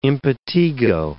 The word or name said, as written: impetigo